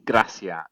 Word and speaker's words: Gracia: His name is definitely Javi Gracia. So it's not misspelled, it's not Garcia.